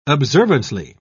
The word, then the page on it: observantly: British English